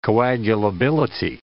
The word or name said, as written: coagulability